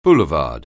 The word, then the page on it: boulevard: anglais